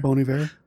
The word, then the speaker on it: Bon Iver: Bon Iver. – Bon Iver.